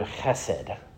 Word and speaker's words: chesed: It's the word chesed. If you don't spit a little bit when you say it, you aren't saying it right.